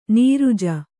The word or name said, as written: ನಿರುಜ